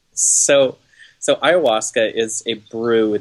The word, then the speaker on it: ayahuasca: I want to know about your experience with ayahuasca.